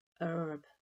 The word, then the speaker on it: herb: Perhaps one of the oddest differences in food words to British ears is the pronunciation of herb, where Americans omit the H sound and say herb.